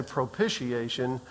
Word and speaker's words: propitiation: I heard it pronounced propitiation all of my life, because that's how my father pronounced it. So what I'm telling you, when I use it and I say propitiation, that is what is considered the correct pronunciation.